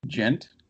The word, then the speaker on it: djent: It's spelled with a D. It's DJENT, but the D is silent.